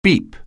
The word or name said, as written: beep